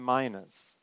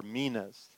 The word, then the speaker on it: minas: Calling 10 of his servants, he gave them 10 minas, or minas. It's pronounced different ways because there's no I in the Hebrew.